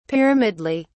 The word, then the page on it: pyramidally: British English